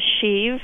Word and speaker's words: sheave: Or it can be pronounced shiv. – Right, shiv. But shiv has such negative connotations of prison and that sort of thing. So we called it a sheave.